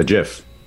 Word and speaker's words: GIF: A GIF. – Which is how it's supposed to be pronounced.